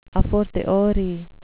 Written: a fortiori

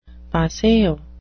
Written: paseo

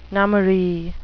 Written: nummary

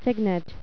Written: cygnet

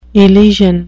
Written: elysian

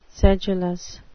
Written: sedulous

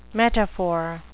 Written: metaphor

